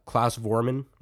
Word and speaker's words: Klaus Voormann: How do you say it again? – Klaus Voormann. – Voormann. I said, okay.